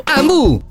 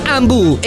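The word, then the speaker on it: Hambu: Ambu è a Varese, Lecco, Meda e Vimercate. Ambu si scrive con l'H.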